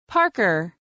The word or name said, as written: Parker